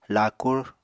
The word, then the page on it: laakur: Apatani